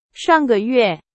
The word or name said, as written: 上个月